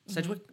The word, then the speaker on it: Sedgwick: Sedgwick, am I saying it right? – Yeah.